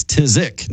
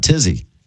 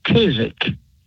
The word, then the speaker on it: phthisic: The full word, believe it or not, is P-H-T-H-I-S-I-C, pronounced tizzic. – Okay. – The P-H-T-H is not involved.